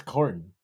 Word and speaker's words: Corden: Why'd you say it like that? – Corden? Is that not how you say his name? – No, I think it's just Corden. – Oh, James Corden. – you're giving him too much fanciness here.